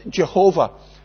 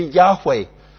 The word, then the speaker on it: Yahweh: Years ago, we used to think it was pronounced Jehovah. Then the scholars told us it should be Yahweh. Jehovah sounds a bit better, but they tell us that Yahweh is the right way to say it.